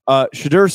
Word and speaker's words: Shedeur: Shedeur Sanders. – That's pretty good.